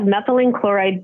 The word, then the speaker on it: methylene chloride: So there are other, am I going to say this wrong, methylene chloride, right? Is that how you say it? – Yes, we have methylene chloride based products as well as non-methylene chloride products.